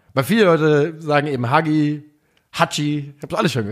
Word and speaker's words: Hagi: Also man muss sagen, Hagi, spricht man das Hagi oder Haji? – Haji. Ja, so wie sein Vater.